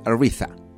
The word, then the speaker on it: Aretha: Claro, así se pronuncia, no como lo dije yo, se pronuncia Aretha.